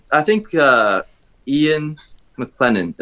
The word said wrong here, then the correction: McKellen: is that how you say his name? – Ian McKellen. – McKellen, said this in a TV show once.